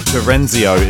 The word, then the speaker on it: Terenzio: Sounds of Andrea Terenzino. I haven't pronounced that right at all, I'm so sorry. Terenzio is the last name, sorry.